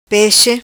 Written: Pexe

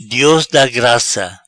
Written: Dios da graca

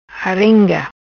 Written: Arenga

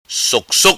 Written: Soc Soc

